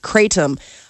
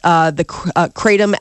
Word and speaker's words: kratom: The Drug Enforcement Administration, the DEA, this week is set to make kratom a Schedule 1 drug. That low doses, kratom acts as a stimulant. – How do you spell it? – K-R-A-T-O-M.